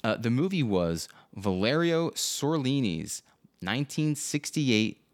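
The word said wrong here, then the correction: Zurlini: The movie was Valerio Zerlini's 1968 film. Did I say that right this time? – It's a Z, not an S. Zerlini.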